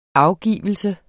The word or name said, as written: afgivelse